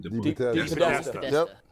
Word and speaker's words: DePodesta: DePodesta.